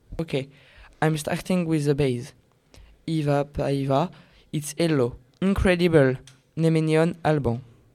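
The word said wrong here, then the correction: hyvää päivää: Actually, it's Hyvä Paiva.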